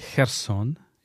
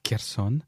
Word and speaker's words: Kherson: Mais d'abord, je fais une note linguistique pour nos auditeurs, que la ville s'appelle Kherson et pas Kherson.